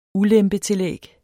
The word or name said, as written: ulempetillæg